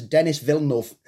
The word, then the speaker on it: Denis Villeneuve: Denis Villeneuve? – Oh, I know exactly who he means by that descriptor name. – Or for those who aren't too sure about like French pronunciations, Denis Villeneuve.